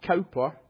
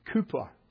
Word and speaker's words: Cowper: That is not Cowper, it is Cooper. That's how you pronounce it.